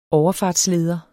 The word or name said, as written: overfartsleder